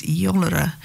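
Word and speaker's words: Iolaire: The song is called the Ialair, which is an anglicised pronunciation of the Gaelic word for eagle, which is Iolara or Iolara.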